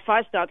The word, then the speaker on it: Fasnacht: Is it Fasnacht? – Yes, you did very well, Fasnacht, because I'm from Pennsylvania, and we have the Amish and the Pennsylvania Dutch tradition.